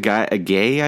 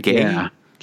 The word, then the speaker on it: Gueye: Idrissa Gueye, I think is how you say it. Gueye.